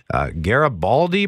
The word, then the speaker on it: Garibaldi: Places like Garibaldi Provincial Park in BC.